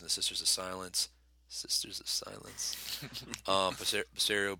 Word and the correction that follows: Prospero: Burning, or Burning of Pesero. – Burning of Prospero. – Prospero. Yeah. I never said it right.